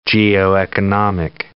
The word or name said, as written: geoeconomic